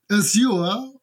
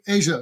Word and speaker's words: Azure: Die Briten sagen Azure und die Amerikaner sagen Asia tatsächlich.